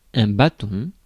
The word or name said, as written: rod